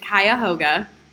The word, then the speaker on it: Cuyahoga: Kiyoga. Kiyoga. – Kaga. It's Cuyahoga Falls. – Anyway. – Or if you want to way over enunciate it, Cuyahoga.